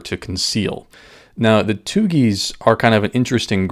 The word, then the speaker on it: Thuggee: So that leads us to the third group I wanted to touch on today, and that's the group called the Tugis. Now, you've probably heard this word before, but may not recognize it with this pronunciation. It's actually spelled T-H-U-G-G-E-E, but it's actually where we get our word thug from, T-H-U-G.